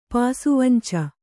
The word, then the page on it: ಪಾಸುವಂಚ: Kannada